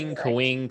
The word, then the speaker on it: Kapwing: I used to say it wrong all the time, but that is Kapwing, Kapwing, Kapwing.